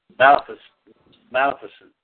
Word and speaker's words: Maleficent: Maleficent, I guess is how you pronounce it. Yeah, that's it. What was David Lean's first film? – David who? – L-E-A-N-S.